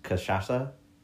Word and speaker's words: cachaça: It's called chisasa. I said it. I butchered it. I'm so sorry. Cash cachaça.